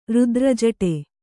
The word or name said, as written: ರುದ್ರಜಡೆ